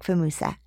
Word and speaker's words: Fumusa: Welcome, Dominic Fumusa.